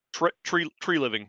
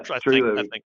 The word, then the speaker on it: Treliving: So I think if, what's his name, Treliving? I can't pronounce his name. – Treliving. – Yeah, Treliving.